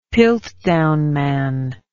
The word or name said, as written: piltdown man